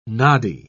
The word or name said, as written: noddy